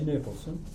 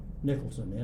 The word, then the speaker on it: Nicholson: Nicholson. – And if you'll spell it? – Douglas, D-O-U-G-L-A-S, initial A, Nicholson, N-I-C-H-O-L-S-O-N.